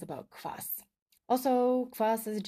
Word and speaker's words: kvass: It's not actually an ingredient, it's like a meal, and it's spelled KVASS. Yes, as in ass.